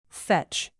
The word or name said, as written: fetch